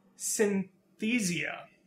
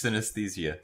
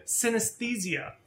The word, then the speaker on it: synesthesia: However, you may choose to compensate for the loss by means of synesthesia. Did I get it? No, I practiced that word. – It's synesthesia. – Synesthesia. I practice, guys. – It's the first time you've said it right.